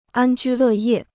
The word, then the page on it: 安居乐业: ān jū lè yè